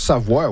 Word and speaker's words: Savoie: Ben, on prononce Savoie, oui. Okay. Il y a certains Américains qui vont dire Savoie, mais généralement Savoie, oui.